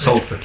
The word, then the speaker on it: Salford: Or is it pronounced Salford? – Salford, yeah.